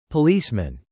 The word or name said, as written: policeman